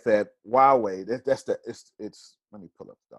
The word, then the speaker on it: Huawei: It's Huawei. It doesn't look like that, but that's how you say it.